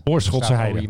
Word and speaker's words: Oorschotse Heide: Hoe spreek je dat eigenlijk uit? Oorschot. – Oorschotse Heide.